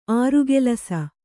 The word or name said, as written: ಆರುಕೆಲಸ